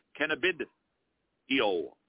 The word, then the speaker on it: cannabidiol: Cannabidiol? Is that how cannabid, cannabid, EOL. It's not oil, it's EOL.